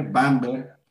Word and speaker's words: Bamberg: Si no, díganme Bamberg, Alemania en 2009, encabezados por los hermanos Mauricio González y Juan de la Boca.